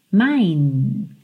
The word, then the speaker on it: mine: Mine. Lembra de não pronunciar o E final.